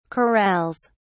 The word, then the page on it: corrals: British English